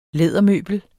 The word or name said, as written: lædermøbel